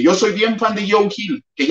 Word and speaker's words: Joe Hill: Tuvo alguien que decirme en el video de Joe Hill que no se pronunciaba yo, eh, Joe Hill, porque yo le decía Joe Hill.